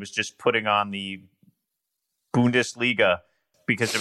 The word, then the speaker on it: Bundesliga: I learned how to say, but I've since forgotten, Bungaldisha. Is that how you say it? – Bungaldisha. – Bundesliga. Right? – Bundesliga.